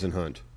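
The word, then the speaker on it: Whisenhunt: Pronounced Wisenhunt. – Is it? – Yeah, Uncle Ken does not go by Wisenhunt.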